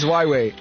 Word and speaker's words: Huawei: I don't know if it's Huawei or Huawei. – Your second attempt was right. – Huawei. No, I can't pronounce that word. – It's Huawei.